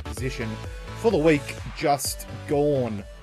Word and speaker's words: Gawn: Yeah, it's a G-O-R-N. – Max Gawn. Yeah. G-A-W-N. – Yeah, we say words weird as well. – Gone. – Yeah, gone, mate. – Technically, it should be gone. G-O-N-E.